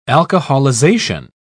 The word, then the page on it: alcoholization: British English